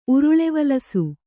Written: ಉರುಳೆವಲಸು

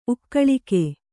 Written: ಉಕ್ಕಲಿಕೆ

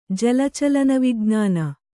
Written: ಜಲಚಲನವಿಜ್ಞಾನ

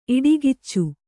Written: ಇಡುಗಿಚ್ಚು

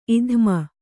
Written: ಇಧ್ಮ

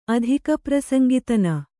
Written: ಅಧಿಕಪ್ರಸಂಗಿತನ

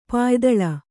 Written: ಪಾಯಿದಳ

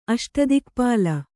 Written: ಅಷ್ಟದಿಕ್ಪಾಲ

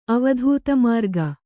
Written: ಅವಧೂತಮಾರ್ಗ

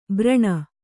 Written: ಭರಣ

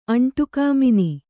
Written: ಅಂಟುಕಾಮಿನಿ

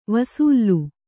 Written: ವಸಲೆ

